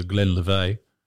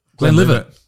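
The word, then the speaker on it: Glenlivet: Use your Glenn Levay. Is that how you pronounce it? Glenn Levay? – Glenn Livett. – That's what I said before, but I feel like it's the Aussie way of fucking it up.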